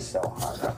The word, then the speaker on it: Sochan: Sochan pronunciation. I mean, he better matter because I'm actually pausing just to... Sochan. It is Sochan.